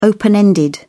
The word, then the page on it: open-ended: British English